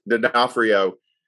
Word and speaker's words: D'Onofrio: D'Onofrio is um, is going to play the villain in that.